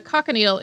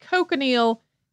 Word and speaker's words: cochineal: The cochineal is a scale insect that's native to Central and South America. Some people also pronounce it cochineal.